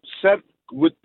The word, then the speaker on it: sandwich: Men ved du, det, altså, hvis de ville have, at man skulle udtale som sandwich, så kunne de jo bare lade være med at putte D med ind i det hele.